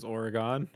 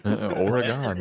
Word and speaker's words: Oregon: The pronunciation is Oregon, apparently. – Oregon, like a polygon.